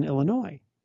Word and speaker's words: Illinois: I grew up in a suburb of Chicago that was near a town called Des Plaines, and you pronounce the S's, but you didn't pronounce the S in Illinois.